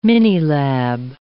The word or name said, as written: minilab